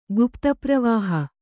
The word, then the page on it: ಗುಪ್ತಪ್ರವಾಹ: gupta pravāha